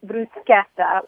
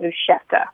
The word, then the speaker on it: bruschetta: We always pronounced bruschetta with a K. And I've been, you know, everywhere it seems people pronounce it bruschetta with like a CH kind of sound.